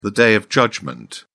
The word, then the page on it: day of judgment: British English